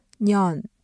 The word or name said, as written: 년